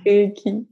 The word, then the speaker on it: Reiki: Ah, aber also ich nach japanischer Aussprache. – Hm. – Reiki.